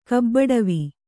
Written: ಕಬ್ಬಡವಿ

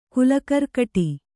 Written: ಕುಲಕರ್ಕಟಿ

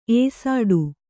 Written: ಎಸಡು

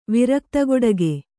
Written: ವಿರಕ್ತಗೊಡಗೆ

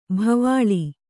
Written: ಬಾವಲಿ

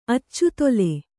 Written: ಅಚ್ಚುತೊಲೆ

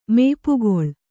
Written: ಮಾಪುಗೊಳ್ಳು

